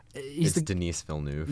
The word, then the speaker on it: Denis Villeneuve: Denis Villeneuve, whose name I will never pronounce right as long as I live. – Denis Villeneuve.